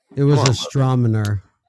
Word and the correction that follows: astronomer: How do you say that? – It's astronomer. – Say it again. Astronomer. – Yeah, astronomer? There you go.